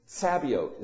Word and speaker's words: Sabaoth: Sabaoth is how it's pronounced.